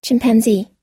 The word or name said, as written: chimpanzee